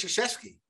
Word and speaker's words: Krzyzewski: Coach Krzyzewski.